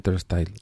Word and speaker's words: Turnstile: Mira, lo he dicho bien, Turnstile.